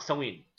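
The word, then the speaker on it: Samhain: اسمه سوين بالانجليزي طبعا ينطق سوين بس كتابته مختلفه لانه اللغه الكلتيه أوكي.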